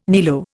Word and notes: niello: Indian

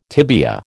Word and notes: tibia: American English

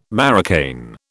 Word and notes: marocain: British English

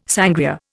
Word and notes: sangria: Indian

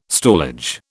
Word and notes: stallage: British English

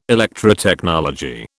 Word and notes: Electrotechnology: British English

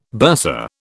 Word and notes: bursa: British English

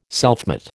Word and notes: selfmate: American English

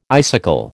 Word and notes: icicle: American English

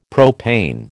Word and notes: propane: American English

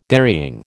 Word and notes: dairying: American English

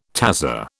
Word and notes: tazza: British English

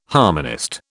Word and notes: harmonist: British English

